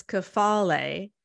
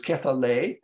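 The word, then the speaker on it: kephale (κεφαλή): It's the correct pronunciation is kephale. – Oh, sorry. Yes. Kephale.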